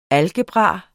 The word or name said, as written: algebra